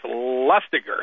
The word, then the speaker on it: Lustiger: Cardinal Lustiger, it's spelled L-U-S-T-I-G-E-R. People always think his name is pronounced Lustiger. That's how I always thought it was pronounced, but it's Lustiger.